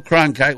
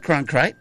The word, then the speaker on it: Cronkite: Will Cronkite will be on at 11 o'clock. And you said it wrong. – I said Cronkite. – That time you did.